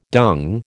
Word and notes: dung: American English

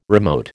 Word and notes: remote: American English

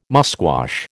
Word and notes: musquash: American English

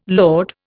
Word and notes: lode: Indian

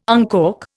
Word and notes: uncork: Indian

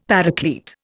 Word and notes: Paraclete: Indian

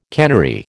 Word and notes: cannery: American English

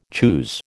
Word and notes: choose: American English

